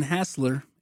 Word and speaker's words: Hassler: Gray, G-R-A-Y. – Paxton M. Gray. Brandon on Snapchat. – Brandon Hassler, H-A-S-S-L-E-R.